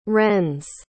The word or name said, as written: renz